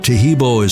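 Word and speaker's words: Taheebo: Tehebo is spelled T-A-H-E-E-B, like boy, O, then the word T, and then the word club.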